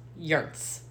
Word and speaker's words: Joens: It's pronounced Yerts. – Yerts. – Yes. – J-O-E-N-S. – Yes. – Is Yerts.